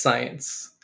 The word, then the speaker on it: Cyence: It's pronounced Cyence. – Cyence with like a C-Y-E-N-C-E or something like that, right? – Yeah, that's exactly right.